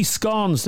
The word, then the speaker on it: scone: No, scone. E is silent. Yeah, the nicest scones. – Sorry, can I the E is silent? – Yeah, it's S-C-O-N, but there's an E at the end of it, but the E doesn't exist. It's like, yeah.